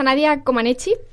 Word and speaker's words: Nadia Comaneci: La primera que os traigo es a Nadia Comaneci, eh, sí, pero lo he dicho bien, sí.